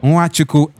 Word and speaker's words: Nwachukwu: My name is Lawrence Nwachukwu Maxwell. Well, you can also follow me on these platforms, on X, Brother Nwachukwu. The brother is spelled B-R-O-D-A. Nwachukwu, N-W-A-C-H-U-K-W-U.